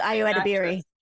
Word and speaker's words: Ayo Edebiri: Well, I was also going to say Ayo Edebiri. I may be really mispronouncing her name, but I think it's Ayo Edebiri.